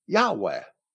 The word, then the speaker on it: Yahweh: And from other writers who were also Christians, who were nearly as early as Josephus, we may determine that those four vowels, which he had in mind, were most likely I-A-U-E, iota, alpha, upsilon, and epsilon, which in English we may naturally pronounce as Yahweh.